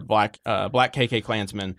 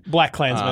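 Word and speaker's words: BlacKkKlansman: Other best pictures or nominations are Black KK Klansman. – Black Klansman, I think is how you pronounce it.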